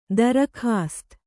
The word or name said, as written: ದರ್ಕಾಸ್ತ್